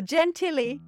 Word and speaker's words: Gentilly: The Francioni family lived in Gentilly, Louisiana. – Gentil. – It's G-E-N-T-I-L-L-Y. – Oh. That is Gentilly.